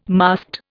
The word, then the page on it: masked: Indian